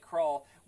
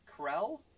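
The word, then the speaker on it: Krull: Yeah, it's kind of funny that it takes over the universe, you know, Planet Krall, which apparently it's not pronounced Krall, because if you listen to the narrator at the very beginning, it's Krell, which they should have changed the spelling.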